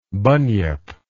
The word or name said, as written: bunyip